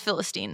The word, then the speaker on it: Philistine: Whether it's yeah. Philistine or Philistine. – I was actually intentionally waiting for you to pronounce it first. Yeah. So then I could say, yeah, it's Philistine.